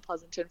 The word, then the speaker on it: Pleasanton: You grew up, or at least laterally, in a town, and I hope I'm pronouncing it right, Pleasanton. Is that correct? – That is correct.